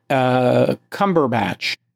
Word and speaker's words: Cumberbatch: If you've never seen the movie with Cumberbatch, am I pronouncing that? That's The Imitation Game. You are pronouncing it correctly.